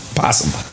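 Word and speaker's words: opossum: There's an O, it's O-Possum, but Possum. – Right. – The O is silent.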